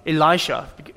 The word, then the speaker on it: Elisha: Elisha.